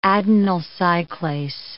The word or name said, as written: adenyl cyclase